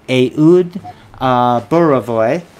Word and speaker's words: Ehud Borovoy: It's the Fledge here with Ehud Borovoy from Dayton, Ohio.